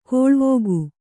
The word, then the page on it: ಕೋಳ್ವೋಗು: kōḷvōgu